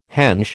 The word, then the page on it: henge: American English